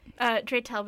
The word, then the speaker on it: Dratel: Dratel, but it doesn't really matter. It's probably in reality like something totally different and has been Americanized over time.